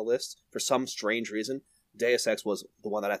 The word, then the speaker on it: Deus Ex: I never know how to pronounce that. – Deus Ex is correct.